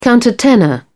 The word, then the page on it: countertenor: British English